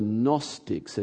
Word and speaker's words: Gnostics: And then while he's trying to figure out how do I respond to this, there was another group of guys called the Gnostics. It's a silent G at the start of that.